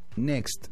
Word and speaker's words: NXT: Next. Se escribe NXT, pero es... – Todos le decimos Next.